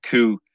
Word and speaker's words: coup: his famous coup, that's how you pronounce it, coup, and he overthrew the Directory of France.